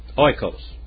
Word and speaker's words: oikos: Oikos. That's where it comes from. Write down oikos. O-I-K-O-S.